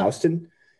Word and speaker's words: Houston: It's like, there's also a street in New York called, it's spelled Houston, which I think of Houston, Texas, but it's pronounced Houston. And apparently you can really like identify yourself as not being from there if you say Houston instead of Houston Street.